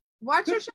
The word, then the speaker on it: Worcestershire: How do you pronounce it? – Worcestershire.